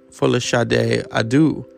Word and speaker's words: Folasade Adu: Folasade Adu.